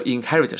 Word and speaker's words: inherit: 这个 inherit 这个继承呢，还有个单词，咱们前两次课上过的，就是这个 succeed 成功啊，succeed succeed succeed 也叫继承，哎，它有一个意思和 inherit 是一样的，注意了啊，发音不要念成 inherit，是 inherit h e 这里是重音啊。